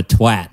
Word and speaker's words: twat: And it's got a woman who it says shots come out hot like farts from a twat. – Hot, I think she should say twat so it rhymes with hot.